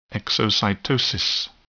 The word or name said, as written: exocytosis